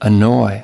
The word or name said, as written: annoy